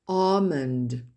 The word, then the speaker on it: almond: salmon, almond, donc vous n'entendez pas la lettre L prononcée, mischievous, etc., specifically, specifically, quinoa, ask, move, ne pas confondre avec to move, bouger, qui s'écrit M-O-V-E.